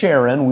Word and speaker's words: Sharon: And all that dwelt at Lydda and Saron, now this is talking about the plain there, right? The plain of Sharon, we'd sometimes pronounce it, saw him and turned to the Lord.